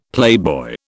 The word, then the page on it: playboy: British English